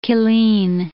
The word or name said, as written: Killeen